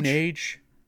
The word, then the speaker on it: Moonage: Moon Age? It might be Moon Age. I think it is pronounced Moon Age in the song, come to think of it.